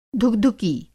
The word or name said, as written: धुकधुकी